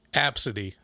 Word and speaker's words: Abcde: Pronounced Absidy.